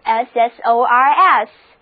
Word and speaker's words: scissors: 第一个单词 scissors SCISSORS 字母组合 SC 读作 SS 读作 Z 最后的 S 也发 Z 的音，跟我读，这个单词念作 scissors。